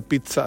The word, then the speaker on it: pizza: Con T, se pronuncia pizza, así la correcta pronunciación, tiene doble Z, se pronuncia TS, pizza.